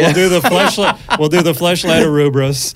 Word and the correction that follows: Ouroboros: Arubros? Am I saying that right? – Ouroboros.